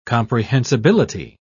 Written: comprehensibility

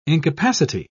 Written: incapacity

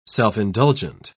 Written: self-indulgent

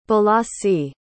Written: balasi

